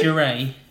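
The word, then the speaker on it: Jarae: Jeraine Nicole Estep, and Nicole is spelled N-Y-K-K-O-L-E. – How's Jerole? Is it Jerole? – Jeray. – No, you literally said it 2 seconds ago. – J-A-R. – J-A-R. – R-A-E. – Jeray, oh, okay.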